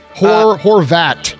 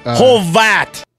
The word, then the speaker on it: Horvat: Hovart. – Yeah, just one R. Just one R. – Hovart. – Horvat. – Horvat.